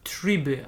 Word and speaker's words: Tribe: Invoxia Tribe, albo Tribe właściwie, tak bardziej po angielsku chyba Tribe.